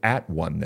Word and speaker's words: atone: But we pronounce it like we do in the old King James English. When you're all by yourself, you're not all one, you're alone. So when we go, we don't at one, we atone.